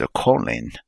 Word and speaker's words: choline: Is it taurine or corin? It's pronounced choline.